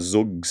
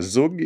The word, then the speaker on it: zhoug: Zug is a coriander relish from Yemen. – Oh. – Yeah. – How do you spell that? – Z-H-O-U-G, or H, zug. I should say, shouldn't I? – It's a what kind of relish?